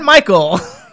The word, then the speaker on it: Mychal: It – so actually how do – so it's Mychal? – John Mychal, yeah. – Okay. Yeah. So No I, no E. It's just as simple as that. – I have been pronouncing it wrong all day, and welcome to the Jimmy Star Show, John Mychal.